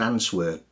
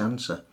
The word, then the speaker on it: answer: And finally, silent W, as in answer or write. So in answer, you would expect it to be pronounced answer, but it's not. It's answer. – Mm-hmm. – If you've got WR at the beginning of the word, the W is always silent.